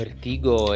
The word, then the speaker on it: Vertigo: Vertigo è italiana. Vertigo è una pronuncia, pronuncia italiana.